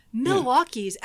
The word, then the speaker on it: Milwaukee: Milwaukee's actually pronounced Milwaukee.